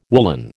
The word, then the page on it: woollen: American English